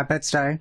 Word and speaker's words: Bed-Stuy: Takes place on a very hot, long day in Bed-Stuy. Is that how you pronounce that? – Yeah, Bed-Stuy.